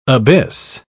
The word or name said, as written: abyss